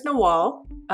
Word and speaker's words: Nawal: So my name is Nawal, and it's spelled exactly how it's pronounced. A lot of people tend to say Noel, and sometimes I let it happen, but I really appreciate when people ask me how to pronounce it and pronounce it right.